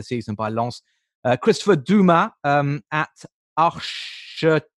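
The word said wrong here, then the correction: Ardèche: Archer, no, Ar- sorry, Ardesh Football.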